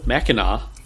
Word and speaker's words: Mackinac: Maple Mackinac.